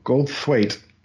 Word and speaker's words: Goldthwait: It is Gold Goldthwait. It is, it is T H W Thwa Goldthwait. – Thwait, yeah. – I am familiar with Mr. Goldthwait.